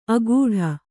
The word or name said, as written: ಅಗೂಢ